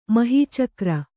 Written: ಮಹೀಚಕ್ರ

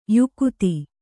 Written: ಯುಕುತಿ

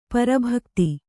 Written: ಪರಭಕ್ತಿ

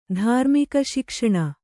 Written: ಧಾರ್ಮಿಕಶಿಕ್ಷಣ

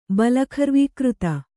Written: ಬಲಖರ್ವೀಕೃತ

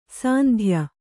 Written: ಸಂದೆಯ

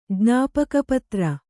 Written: ಜ್ಞಾಪಕಪತ್ರ

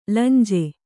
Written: ಲಂಜೆ

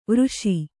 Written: ವೃಷಿ

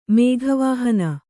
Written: ಮೇಘವಾಹನ

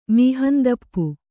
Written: ಮೀಹಂದಪ್ಪು

